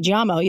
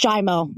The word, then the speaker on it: Giaimo: So the correct pronunciation in Italian is actually Giaimo. You have it, but it's Giaimo. Just Giaimo.